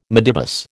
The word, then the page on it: midibus: American English